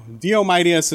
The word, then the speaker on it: Diomedes: Diomedes is the pronunciation.